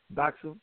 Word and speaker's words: Doxa: One of the things I want to let you know that it's not just D-O-X-A. It is actually pronounced Doxa. – Doxa? – Doxa, correct. Yep.